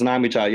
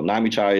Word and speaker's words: Nammychai: It's Nammychai. Yeah, because, you know, in service, we go by last name. So everyone just called me Chai. So it was pretty easy to say. So Nammychai is how you say it, though.